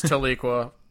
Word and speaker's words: Tahlequah: They do have a Walmart in Tahlequah, which I'm going to still say is pronounced Tahlequah. – It is Tahlequah. Everyone else is wrong.